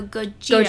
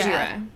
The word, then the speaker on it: Gojira: gorilla in Japanese, and I could be pronouncing this completely wrong, is gorira, and then whale is kujira. – So okay, yeah. Go- so Gojira. Gojira. and then in the Americanized version, Godzilla.